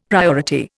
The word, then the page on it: priority: Indian